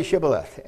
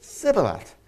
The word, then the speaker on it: Shibboleth: And they said to him, "Please say Shibboleth." And he said, "Sibboleth," because he could not manage to pronounce it right.